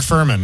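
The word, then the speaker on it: Fuhrman: Yesterday we learned that Mark Farman, how do you say his name? Farman, that guy from the O.J. Simpson trial. – Yeah, the cop, right? – Yeah, he died. – Mark Furman. Yeah.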